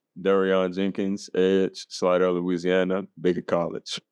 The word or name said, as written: DeReyon Jenkins